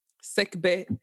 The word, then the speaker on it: Nsegbe: Sekbe. Daniel Alain. Alain. Sekbe qui s'écrit N-S-E-G-B-E.